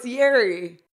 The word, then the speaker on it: Yary: No, it's Yary, like every other A-R-Y word ever.